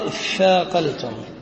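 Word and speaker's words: اثّاقلتم: أصله تثاقلتم أبدلت التاء ثاء ثم أبدلت التاء أبدلت التاء ثاء فصار الثاءين فقال اثاقلتم. واجتلبت همزة الوصل للتوصل بالنطق للتخلص من ساكن.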